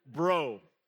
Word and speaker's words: Breaux: Now, my last name is pronounced Bro, like B-R-O. It's spelled B-R-E-A-U-X.